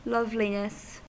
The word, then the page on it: loveliness: British English